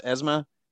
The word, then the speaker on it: ESMA: Is there any, Nikki, you were saying earlier about, like, is it pronounced ESMA? – Yes.